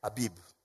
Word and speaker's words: Aviv: The Hebrews called it the month of Abib or Aviv, that's how it's pronounced.